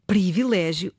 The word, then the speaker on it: privilégio: Ah, mas antes disso, Filomena, de facto a palavra escreve-se privilégio e deve pronunciar-se em contexto formal, em registro de cuidado, de acordo com a norma padrão, privilégio. Mas porquê que a pronúncia privilégio é, não é, uma pronúncia tão comum?